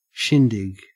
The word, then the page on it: shindig: Australian English